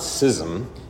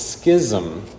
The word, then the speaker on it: schism: And even now, there are some in this room that are saying, it's not schism, it's schism. When have you ever heard of an English word with a silent CH? School, schism. And the others reply, oh, no, no, no, no. When was the last time you cut a piece of paper with a pair of scissors? Scissors, schism.